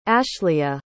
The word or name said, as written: ashlea